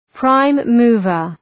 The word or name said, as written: prime mover